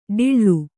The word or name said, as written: ಡಿಳ್ಳು